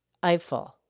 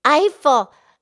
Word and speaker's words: Eiffel: No, I probably said that wrong. I'm trying to put an accent in there. – Oh, no, try again. – Gustave Eiffel. Eiffel. – Oh, Eiffel. Oh, the guy that built the Eiffel Tower, of course.